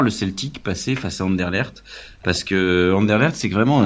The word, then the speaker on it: Anderlecht: En tout cas, en Belgique, sur les chaînes belges, ils disent Anderlecht. Donc bon, c'est qu'a priori, les Belges, ils savent comment prononcer le nom de leur propre ville.